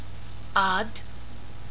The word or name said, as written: Aad